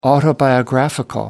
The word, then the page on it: autobiographical: British English